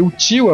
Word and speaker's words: Uchiwa: Só que seria Uchiwa a pronúncia correta.